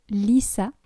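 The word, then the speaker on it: Litha: La fête contemporaine du solstice d'été pour les païens est appelée Lissa, qui s'écrit Lita, T-H-A.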